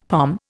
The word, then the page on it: pom: Indian